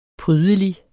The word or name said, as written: prydelig